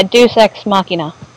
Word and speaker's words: Deus Ex Machina: You basically had two Deus Ex Machina, or however that's pronounced. – So it's... – Yeah, Deus Ex Machina.